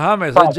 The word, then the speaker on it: James: Sí, aquí se aquí le dicen James Rodríguez, aquel jugador colombiano, y no es James, es James.